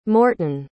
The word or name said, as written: morton